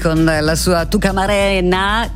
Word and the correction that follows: Tucamacarena: che è Tucamacarena, scusate, io ogni volta che pronuncio questo titolo mi sbaglio perché è quasi uno scioglilingua. Simpaticissimo comunque questo pezzo, davvero molto molto estivo.